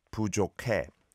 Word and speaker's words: 부족해: 부족해, 부족해. 부족해 is how it is spelled, but you don't need to overly pronounce the hiat, I would say.